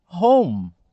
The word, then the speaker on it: home: Mas vamos dar uma olhada aqui então na palavra home. Home Olhando como brasileiro, eu vou pensar, nossa, ela é de sílaba, ela tem duas sílabas, home. Não, mas não é home.